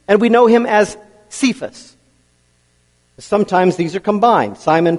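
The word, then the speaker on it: Kephas: There's also the word for rock, actual rock, I mean, and it's pronounced Cephas, spelled with a K, and it means rock.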